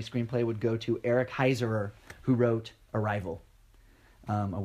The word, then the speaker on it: Heisserer: Heisserer.